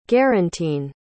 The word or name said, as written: guarantine